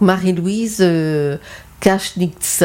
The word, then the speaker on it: Marie-Louise Kaschnitz: Donc, maintenant, on va vous parler d'une autrice, donc Marie-Louise Kaschnitz.